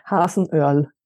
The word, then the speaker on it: Hasenöhrl: Hasenöhrl.